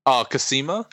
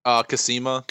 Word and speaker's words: Cosima: Cosima? – That's the one.